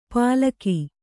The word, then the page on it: ಪಲಕಿ: palaki